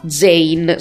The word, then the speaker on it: Zein: Il canale si chiama Mirko con la K Zain, scritto Z-E-I-N, proprio Zain, così come si pronuncia.